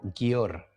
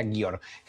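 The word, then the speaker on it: Győr: Yo lo pronuncio como Gior, pero seguramente aquí me van a ver feo diciendo así no se pronuncia, pero yo diría Gior. G-Y-O-R.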